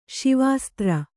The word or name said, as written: ಶಿವಾಸ್ತ್ರ